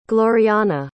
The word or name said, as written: gloriana